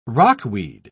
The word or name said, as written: rockweed